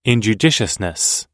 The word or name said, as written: injudiciousness